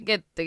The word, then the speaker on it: Goethe: Goethe, Goethe.